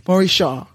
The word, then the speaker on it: Morishaw: Morisha.